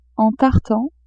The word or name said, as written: en tartan